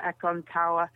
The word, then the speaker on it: Aconcagua: But I miss the Americas, so I'm booked in for South America, Aconcagua, which is how the Spanish pronounce it in the in South America, in Chile or Argentina.